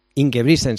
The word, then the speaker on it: Ingebrigtsen: Y para ello nos vamos a fijar en la figura de Jakob, a ver si lo digo bien, se dice Ingebrigtsen. – Ingebrigtsen, sí.